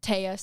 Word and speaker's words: Taya: I'm at Taya C, but there's a bunch of A's.